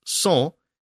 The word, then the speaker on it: cent: So in the case of the French word 100, C-E-N-T, pronounced cent, it becomes anglicized to cent,